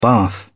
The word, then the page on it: bath: British English